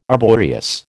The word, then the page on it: arboreous: American English